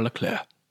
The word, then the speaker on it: Leclerc: Spelled Charles Leclerc. – Yes. – But pronounced Charles Leclerc.